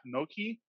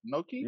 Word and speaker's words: gnocchi: So now we're looking at Noki. Uh, am I saying that correctly? Noki. – Gno- yeah, it's gnocchi. Yeah. yeah. Gnocchi. Yeah. You you, you said it good.